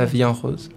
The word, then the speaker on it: La Vie en Rose: Pero se llama La Vida en Rose, no sé cómo se pronuncia. – La Vie en Rose.